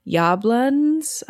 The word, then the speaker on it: Yablans: independent film producer Erwin Yablans, I believe is how it's pronounced, actually sought out John Carpenter to direct a film about a psychotic killer that stalked babysitters.